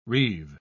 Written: reave

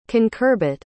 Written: concurbit

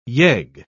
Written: yegg

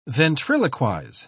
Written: ventriloquize

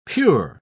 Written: pure

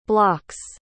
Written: blocks

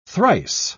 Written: thrice

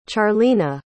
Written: charleena

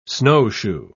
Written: snowshoe

